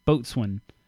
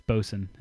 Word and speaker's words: boatswain: Boatswain. It's bosun is how that's pronounced.